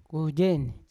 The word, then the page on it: kuejewen: Yawa